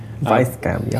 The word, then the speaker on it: Weisscam: No, I think it's pronounced Weisscam because it's spelt with a W but said by Germans. – Right. Weisscam, yeah.